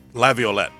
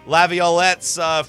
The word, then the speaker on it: Laviolette: Now questions surround about Peter Lafayette's, how do you say his name? – Laviolette. – Laviolette's future and whether or not the Preds make a change.